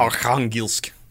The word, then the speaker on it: Arkhangelsk: But it's Archangelsk. Mm. I looked up the pronunciation. I can't do it. – Mm-hmm. – Arkhangelsk. I don't know. – Perfect.